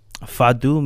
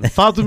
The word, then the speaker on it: Fado: So I think from now we're going to go to something called Fado Music. – Good for you. Yeah. I love that pronunciation. Fado Music.